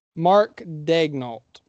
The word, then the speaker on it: Daigneault: What can you tell me about, I can't pronounce it, it's Daniel? – Mark Daigneault. – Mark Daigneault. Thank you. – Yeah, it's a fun pronunciation.